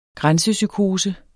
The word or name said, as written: grænsepsykose